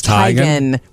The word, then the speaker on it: Teigen: It's Teigen. Isn't it, Mom? – Yep. It's Teigen. We've been pronouncing her name wrong all along. Chrissy Teigen is not her name. It's Chrissy Teigen.